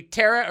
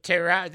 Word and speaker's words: Terah: That could be Terah or Terah. That's fine. It doesn't. The pronunciation's not big. It does depend on whether you're from Detroit or L.A., I suppose.